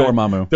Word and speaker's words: Dormammu: Is it pronounced Dormammu? How do you pronounce that guy? – Dormammu.